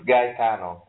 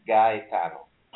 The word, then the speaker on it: Gaetano: It's Gaetano with an hard G. Gaetano.